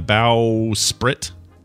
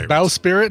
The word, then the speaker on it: bowsprit: Bowsprit. Oh, bowsprit. Yeah, bowsprit. – Bowsprit. Is that how they say it? – Hmm. – I don't know that I knew that. – I don't know what that is. I read that pirate as bow spirit, and I was thinking, oh, maybe that's like the thing, the thing that comes off the front of it, the bow.